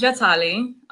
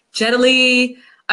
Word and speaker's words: Jatali: So it's funny, the Italians, and I feel like people of other cultures tend to say it properly, but then New Yorkers always butcher it. So they're always like, Jatali. I've even gotten Jet Li. I'm like, no, it's J-A-T-A-L-I.